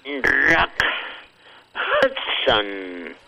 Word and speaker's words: Rock Hudson: Doña Kika, no se dice Rod Hudson. Se dice, déjeme fruncir el hocico porque es la única manera de poder pronunciar palabras en inglés con el hocico fruncido. El actor se llama Rock Hudson.